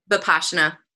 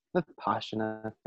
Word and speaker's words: Vipassana: Am I saying that right? – Vipassana. – Vipassana. Thank you.